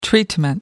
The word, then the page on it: treatment: American English